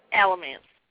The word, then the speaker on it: Alamance: And I can't read because I can't pronounce Alamance right. – Alamance. – Yeah, that's what I said, Alamance.